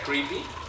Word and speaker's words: creepy: Bueno, en realidad se pronuncia creepy. Es una palabra gringa.